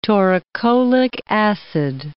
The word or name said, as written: taurocholic acid